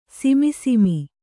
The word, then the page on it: ಸಿಮಿಸಿಮಿ: simi simi; kannada